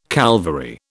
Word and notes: Calvary: British English

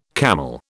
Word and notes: camel: British English

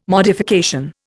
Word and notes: modification: Indian

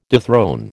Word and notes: dethrone: American English

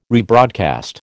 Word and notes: rebroadcast: American English

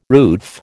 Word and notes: roof: American English

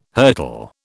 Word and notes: hurtle: British English

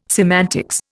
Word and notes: semantics: Indian

